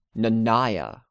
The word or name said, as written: Nanaia